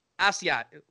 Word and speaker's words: Asiasi: Asiasi.